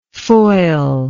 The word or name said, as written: foilist